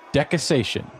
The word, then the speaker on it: decussation: Decussation. D-E-C-U. – Decussation. – I promise you, that's how you say it. D-E-C-U-S-S. – I was counting on double S. – A-T-I-O-N.